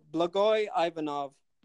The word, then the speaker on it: Blagoy Ivanov: Oh, Blagoy Ivanov.